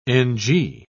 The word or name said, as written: ng